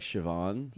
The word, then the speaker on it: Siobhan: But Well, because in Irish, BH is V. MH is V. – Oh, like Siobhan? – Yeah, it's pronounced with a V.